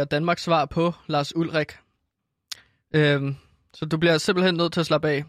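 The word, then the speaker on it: Ulrich: Lars Ulrik som det egentlig udtales.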